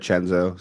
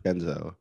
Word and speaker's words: Cenzo: Yeah, with promo code Chenzo, C-E-N-Z-O. – Yeah. – Chenzo. – Senzo. – Senzo. If you would, hey, if you would have pronounced it like that, but you use the code, go right ahead.